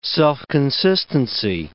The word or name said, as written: self-consistency